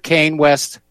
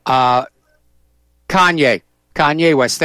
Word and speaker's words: Kanye: Now, do you remember, I'm not 100% sure how to pronounce his name, Kanye West or Kane West? Kanye West, thank you.